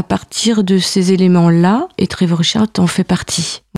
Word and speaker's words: Trevor Wishart: Est-ce que tu peux nous parler de Trevor Wishart, si je le prononce bien? – Oui, tout à fait bien.